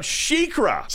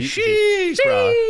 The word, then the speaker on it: SheiKra: We're talking about SheiKra. – That's not how you say it. – You say SheiKra. – SheiKra. – She is SHEI capital K. – That's goes R-A.